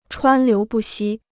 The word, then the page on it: 川流不息: chuān liú bù xī